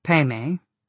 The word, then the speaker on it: padme: Peme, pronounced Peme by the Tibetans, means lotus and refers to the wisdom aspect of the path.